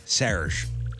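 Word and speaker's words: Serj: When I interviewed Serge Tankian, he said, it's Serge.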